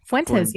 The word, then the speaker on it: Fuentes: Fuentes. Yeah, that's pretty good. Yeah, Fuentes.